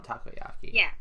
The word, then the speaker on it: takoyaki: They end on takoyaki.